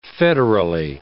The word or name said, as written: federally